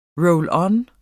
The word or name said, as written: roll-on